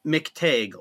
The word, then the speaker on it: McTague: McTague, long McTague. – A.